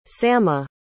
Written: samma

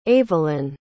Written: avalyn

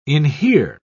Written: inhere

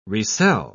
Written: resell

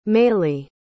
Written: maylee

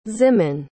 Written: ximen

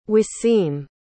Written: Wassim

